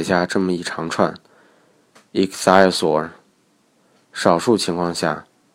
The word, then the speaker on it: ichthyosaur: 而那些完全符合拼读规则的单词，其实百分之九十六以上的英文单词基本上符合拼读规则。就基本上无需标注音标了，比如 ichthyosaur 这个单词，ch 的读音是 k，y-u-o 的读音是 u，a-u-r 的读音是 or，重音在第一个音节。